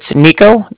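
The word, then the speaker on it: Niko: It's Nico, that's N-I-K-O.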